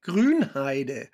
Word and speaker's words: Grünheide: Grünheide. – Oh, Grünheide. Yeah. Did I pronounce that correctly? Grünheide.